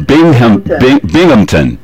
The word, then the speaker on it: Binghamton: Most people have a problem saying this town's name. Even Ronald Reagan did, so don't feel no way about it. It's Binghamton. B-I-N-G-H-A-M-T-O-N. So you pronounce that Binghamton.